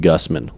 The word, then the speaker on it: Gusman: I'm Tyson Gusman, like that.